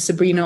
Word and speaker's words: Sabrina: And Sabrina, obviously, like the teenage witch.